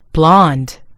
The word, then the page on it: blond: American English